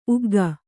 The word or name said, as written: ಉಗ್ಗಿ